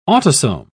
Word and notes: autosome: British English

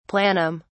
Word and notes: planum: British English

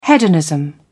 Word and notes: hedonism: British English

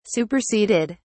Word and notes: superseded: British English

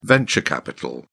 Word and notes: venture capital: British English

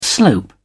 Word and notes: slope: British English

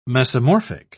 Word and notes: mesomorphic: British English

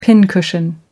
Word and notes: pincushion: American English

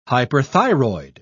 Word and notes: hyperthyroid: British English

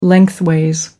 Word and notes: lengthways: American English

